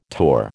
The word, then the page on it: torr: American English